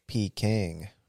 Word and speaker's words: Peking: Peking. – Oh, you're looking at Peking. – No, it's Peking. Like, pecan. – Like peak. Oh, yeah, pecan. – Yeah. – Like pecan duck.